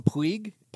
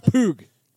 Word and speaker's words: Puig: I used to call him Puig, Pweeg. – And then I realized through the LIV broadcast that it's Pug. – It's Pug. – Pug.